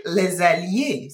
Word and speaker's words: les alliés: So les alliés? Les alliés. – C'est ça? – Oui, c'est ça.